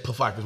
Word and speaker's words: Pfeiffer: Pfeiffer's, we pronounce our P's around here.